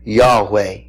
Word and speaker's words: Yahweh: The Hebrew is Yod-Heh-Vav-Heh, sometimes pronounced Yahweh.